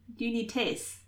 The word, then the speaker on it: d'Unités: Oh, you were pretty close to right. d'Unités. – Great. – d'Unité. Yeah. – D'unité. – D'unité. Yeah. As you would pronounce if there was a vowel coming after it in the next word.